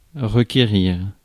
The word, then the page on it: summon: /'sʌmən/